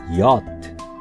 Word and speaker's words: yacht: チャールズ 2 世はこのプレゼントに大層喜び、このような船の名をオランダ語の発音を参考に英語の綴りで Y, A, C, H, T 発音するとヤッ t と改めたのです。